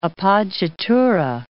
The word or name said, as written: appoggiatura